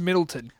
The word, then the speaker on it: Middletown: Not just with the humble successes of two boys from Middletown, Connecticut, but also with the distinct aura... – It's pronounced Middleton.